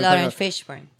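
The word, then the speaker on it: Laurence Fishburne: Lauren Fishburne.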